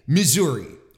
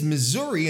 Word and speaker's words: Missouri: And from what I understand, like there's no really, from what I understand, there is no concrete proper way to say Missouri or to name the state. You can say Missouri or Missouri. But apparently, like, the oldest way to pronounce it is Missouri, and this would probably be due to the heavy French influence in the state, where they would pronounce it in a French way, because obviously, you know, originally the term is Native American, right?